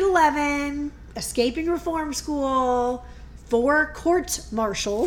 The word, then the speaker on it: courts-martial: Unfortunately, his army career didn't last long because he had four courts-martial, which I found out is the correct way to say it, not court-martials.